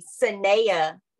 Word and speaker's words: Sanaa: And they heard Sanaa say her name is actually Saneya Lathan, but she lets people call her Sanaa because everybody just messes it up.